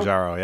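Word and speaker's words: Mounjaro: Is it Mergerno? Is that Mangiaro? How you say it? – Oh, yeah. – Mangiaro? – Mangiaro, yeah.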